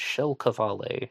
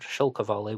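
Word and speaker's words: Kjell Qvale: Mike Rodelli believes that the Zodiac killer was a Norwegian-American named Kjell Kavale. His first name is spelled Kjell, K-J-E-L-L, and Kavale is spelled Q-V-A-L-E.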